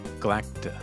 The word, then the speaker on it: Galacta: Galactica. But you put in an extra syllable. – Oh, Galacta.